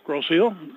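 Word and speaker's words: Grosse Ile: Or is it Grosse Isle? – Grosse Isle. – Grosse Isle. – Yep. – But there aren't really any eels. – No, but it is pronounced Grosse Isle. – That's right, French.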